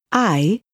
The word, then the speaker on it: hay: It's spelled H-A-Y, but the Spanish H is always silent, so whenever you see the letter H at the beginning of a word, just pretend it's not there.